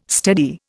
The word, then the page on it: steady: Indian